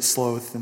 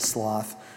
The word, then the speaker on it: sloth: I think the Brits say it sloth, and we say it sloth.